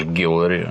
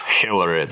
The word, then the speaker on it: Хиллари: А почему ты ее зовешь Гиллари, а не Хиллари? – О, ну, потому что в России она Гиллари. И более того, я ее не люблю, поэтому, во-первых, я не хочу произносить ее имя правильно.